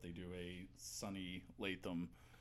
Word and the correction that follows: Landham: Landham. – Landham. Yeah. Landham, right. Since there's no T in H, it probably wouldn't be Latham.